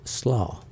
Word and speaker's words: SLA: abbreviated SLA and pronounced SLAW, come off.